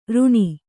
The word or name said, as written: ಋಣ